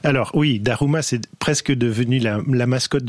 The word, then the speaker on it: Daruma: Ça s'appelle un Daruma.